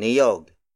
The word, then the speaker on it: niyog: For example, tatlong niyog.